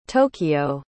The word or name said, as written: tokyo